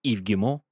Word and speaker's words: Yves Guillemot: So Ubisoft CEO Yves Guillemot has commented, that's how you pronounce it by the way, has commented on recent speculation that the company could be the subject of a takeover bid.